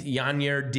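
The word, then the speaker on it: Yainer: Yanier Diaz. Did I do it? Did I mispronounce? Yanier. – Rhymes with sigh.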